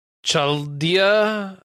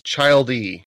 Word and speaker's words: Chaldea: So here's the here's the core concept though for this is is it takes place in 2015 and uh the Chaldea, I want to say it's pronounced. I thought it was Childea. C H A L D E A. I mean, like, well, child. You could call it child.